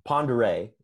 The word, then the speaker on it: Pend'Oreille: Because I went up to Pend'Oreille is how it's pronounced. But it don't look like Pend'Oreille.